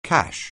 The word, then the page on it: cash: anglais